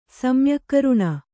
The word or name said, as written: ಸಮ್ಯಕ್ಕರುಣ